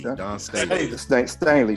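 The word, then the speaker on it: Dawn Staley: Former WNBA player Don Staley. Am I saying that right? – Don Staley.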